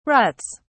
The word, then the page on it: ruts: British English